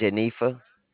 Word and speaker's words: Jenetha: J-E-N-E-T-H-A. – That was J-E-N-E-P-H-A. Is it pronounced Janetha? – Janetha, T as in Thomas, T-H-A. – T-H-A. So that's J-E-N-E-T-H-A.